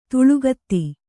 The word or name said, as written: ತಲೆಗುತ್ತ